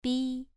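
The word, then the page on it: bi: tone 2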